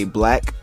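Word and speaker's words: 6LACK: 6 Lack, aka Black, even though it's spelled like 6 and Lack, but don't ask me why or how that even makes sense.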